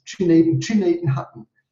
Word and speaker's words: Gnadenhütten: Gnadenhütten, which you pronounce Chinatenhutten.